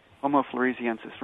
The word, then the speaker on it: Homo floresiensis: It's Homo F-L-O-R-E-S-I-E-N-S-I-E. – All right. – S. – Right. Flores. Floresiensis, right? – Right. – Homo floresiensis, right.